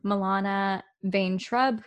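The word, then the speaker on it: Milana Vayntrub: There are a bunch of people, I might be mispronouncing their names, so I'm so sorry, but Milana Vane-Trubb, who played Squirrel Girl.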